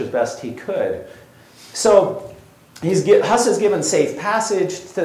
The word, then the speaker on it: Hus: Hus, his last name is Bohemian. It's pronounced Hus, goose. He is a goose. That's his name, means goose.